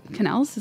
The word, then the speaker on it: quenelle: When I got there and looked around, I saw that all these chefs were making elaborate ice carvings, putting together their salmon quenelles. Quenelles? Is that how you pronounce it? Quenelle? I think so. Help me out. Quenelles? – Quenelle. – Quenelle. Great.